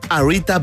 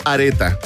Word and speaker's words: Arita: Hoy te recomendamos Arita Bar también conocido como areta, si es que pronuncias mal el inglés. Claro. Pero si pronuncias bien, arita, ¿cierto?